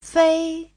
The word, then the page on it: fei: fei1